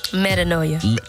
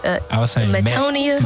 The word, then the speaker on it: Metanoia: Metanoia. A lot of people say Metonia.